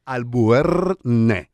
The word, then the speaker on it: Albuérnez: Igual, es a través de las redes sociales, Julia Albuérnez, así como se oye, lo dije, lo pronuncié perfecto, Julia Albuérnez.